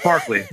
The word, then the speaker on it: Barclay: It's Barclay. – When they're all bullying Barclay and you're like, Jesus, guys, what's going on?